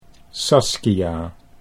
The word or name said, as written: Saskia